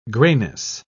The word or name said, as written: grayness